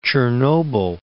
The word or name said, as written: Chernobyl